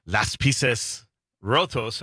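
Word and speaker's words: Las Piezas Rotas: And I believe, Busted Pencils is one of the great shows that she produces, or Las Pieces Rotos. I just learned that. That's how you say it in Spanish.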